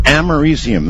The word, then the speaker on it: americium: americium. That's it. That's how you pronounce it.